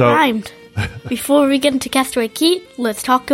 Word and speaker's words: Castaway Cay: And it is Castaway Key, even though it is spelled C-A-Y-K.